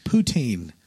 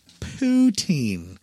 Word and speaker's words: poutine: Poutine. – Poo what? – Poutine. Like, have you been pooting all day?